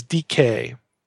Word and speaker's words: dike: Now, the Greek word for justice is dike, D-I-K-E, pronounced long E, dike.